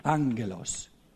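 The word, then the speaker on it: angelos (ἄγγελος): Eh, angelos, quindi doppia gamma si legge come G, come NG, angelos.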